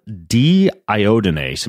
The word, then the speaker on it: deiodinase: You talk in the book about an enzyme called deiodinase.